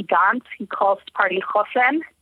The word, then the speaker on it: Hosen: In English, it's spelled like Hosen, which people are like, why did he name his party Pants in Yiddish? But he did not. He named his party Chosen, which is resilience in Hebrew.